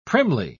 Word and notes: primly: British English

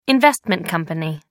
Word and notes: investment company: American English

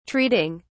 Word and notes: treating: British English

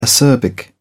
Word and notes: acerbic: British English

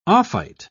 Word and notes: ophite: British English

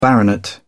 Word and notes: baronet: British English

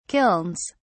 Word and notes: kilns: British English

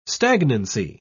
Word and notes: stagnancy: British English

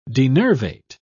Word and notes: denervate: British English

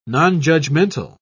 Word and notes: nonjudgmental: British English

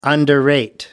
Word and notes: underrate: American English

